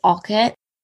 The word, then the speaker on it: Alcott: So we talked about this a lot before we started recording, because at Orchard House, we do say that the pronunciation is Alcott.